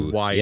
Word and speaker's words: Ys: Ys, or what, however you pronounce the Y-S. – Yeah, Ys. You are correct.